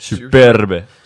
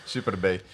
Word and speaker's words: Superb: Ehm, eh, de Bentley Flying Spur en de Skoda Superb. Ik kan het amper uitspreken. – Superbe. – Superbe.